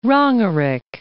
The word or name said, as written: Rongerik